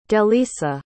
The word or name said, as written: delisa